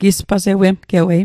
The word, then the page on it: gispasawemgewei: Mi'gmaq